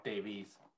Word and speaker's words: Davies: His last name is spelled weird. It's D-A-V-I-E-S. – Oh, like Davies. – Yeah, but it's pronounced Davies.